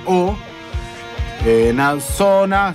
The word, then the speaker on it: Oona Zoona: Se escribe O-O, o sea, doble O. Na Zona, Z-O-O-N-A. O sea, O-O-N-A, Z-O-O-N-A. Ona Zona.